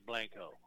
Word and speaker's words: Blanco: The locals say Blanco. So you go down there and say Blanco, they'll know you're not from around here.